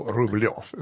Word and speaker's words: Rublev: It's a very specific Cyrillic letter that's got like kind of a yeah sound. So it's Ru. And if it's in a certain place in the, it's all the thing. So Rublev is how you pronounce it.